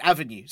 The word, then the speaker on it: Avenue: We've had this question, and it's been sent in by, I think, Ave, I might, or Ave. I'm not sure if I'm pronouncing this right. A-V-E. – I think it's short for Avenue. – Avenue, right? That's it, Avenue.